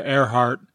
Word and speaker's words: Earhart: The first syllable is spelled like a body part but isn't pronounced like one, and the second syllable is pronounced like a body part but isn't spelled like one. Who is this famous woman? And the answer is Amelia Earhart, as in ear and heart.